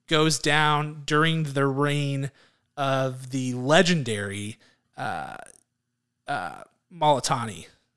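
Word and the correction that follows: Moltisanti: Is that the right way to pronounce that? – Maltasanti. – Maltasanti, sorry. Mm-hmm.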